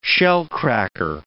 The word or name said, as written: shellcracker